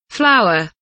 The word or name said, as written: flour